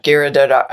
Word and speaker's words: Giridharadas: Anand Giridharadas. – Giridharadas. – Giridharadas.